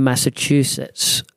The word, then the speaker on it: Massachusetts: vanished without a trace from the home in Massachusetts.